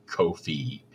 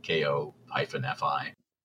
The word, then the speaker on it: Ko-fi: And if you don't like sort of the recurring aspect of Patreon, there's, I never know how to say this. I know it's supposed to be just coffee, but the Ko-fi page thing, Ko-fi,